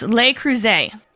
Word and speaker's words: Le Creuset: It's Le Creuset. – Okay, Le Creuset.